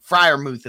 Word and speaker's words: Freiermuth: I mean, Freiermuth is how you pronounce Freiermuth, Freiermuth, Frank Footer, whatever.